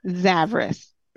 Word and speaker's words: Zavras: Zavras.